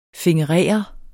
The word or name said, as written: fingerere